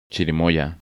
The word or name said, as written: Chirimoya